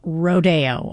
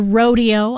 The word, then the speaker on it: Rodeo: Buckaroo Holiday from Rodeo, or Rodeo. I know it's pronounced both ways. Aaron Copland wrote the music and apparently pronounced it both ways himself.